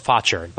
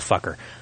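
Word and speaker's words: Faucher: That's Fotcher, by the way, not Fucker.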